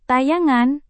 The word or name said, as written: tayangan